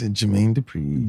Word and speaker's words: Jermaine Dupri: And of course, my last little bit of music news is Jermaine Dupri. Talking about all female. – Why you say his name like that? – What is it, not Dupri? – I think it's Jermaine Dupri.